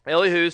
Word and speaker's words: Elihu: I've heard it pronounced Elihu and Elihu. I'm going to go with Elihu.